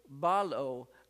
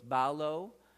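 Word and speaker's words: balo (βάλλω): Now, when I put balo, I don't put a lot, it's a long O, and that's how, and many times you're going to see it with the omega at the end, that's a long O, like in October, October, it's a long O, so it's balo,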